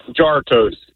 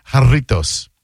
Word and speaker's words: Jarritos: Pronounced Jartos. – What happened? – Pronounced Jartos. – Well, I guess if you're a dumbass, it is. But no, it's Hispanic, so it's pronounced Jarritos.